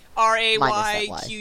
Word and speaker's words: Rayquaza: Okay, so if you, because you guys are on a podcast, you can't I'd spell it Rayquaza, Rayquaza, but it's actually supposed to be Rayquaza. And you know how I can remember now? Because it's like Quasar.